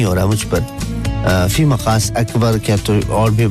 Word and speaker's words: مقاس: اصل میں اس کو جو تحریری زبان ہوتی ہے اس میں اس کو اس طرح لکھتے ہیں, میم قاف الف سین مقاس, لیکن جب ہم اسے اپنے عام لہجے میں یوز کر رہے ہوتے ہیں تو ہم اس کے تلفظ کو تھوڑا قاف کو گا بنا دیتے ہیں, مگاز کہتے ہیں.